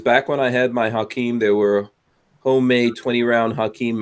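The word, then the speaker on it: Hakim: I've seen people to use maybe it was MG13 mags and modify them for the Hakeem. Mm-hmm. It would be cool. I just haven't got around to doing it. – Yeah, and Flopping Garbage says you said it correctly.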